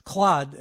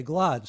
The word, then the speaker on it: Glaude: I don't want to be a Claude and not say Glaude. So let's leave it, get the pronunciation exact here.